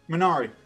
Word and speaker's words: Minari: Minari.